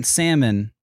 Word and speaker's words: salmon: I was cooking salmon the other day for us, and I realized that in my head, I pronounced it salmon because it's fun to do because there's a silent L there.